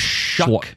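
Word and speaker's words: Sluck: Is that how you say his name? Slough? – Shuck. – Whatever. – It doesn't look like it, but it's Shuck because it rhymes with another word you would say if you were betting on him last season.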